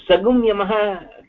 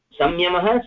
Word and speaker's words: संयमः: sagumyamah can we pronounce it that way sagumyamah samyamah sagumyamah no mohoday I have not heard such uchcharan I have not heard such uchcharan samyamah eva